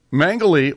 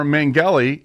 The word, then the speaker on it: Mengele: Mengele or Mengele, and it is pronounced either way. It depends on what country you're in.